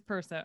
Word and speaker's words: Perso: It is Perso.